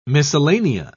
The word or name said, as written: miscellanea